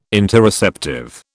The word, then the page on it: Interoceptive: British English